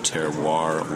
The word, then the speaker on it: terroir: You know, I had to do that because I have a hard time saying terroir. Did I say it right? – You said it right.